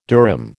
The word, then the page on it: durum: American English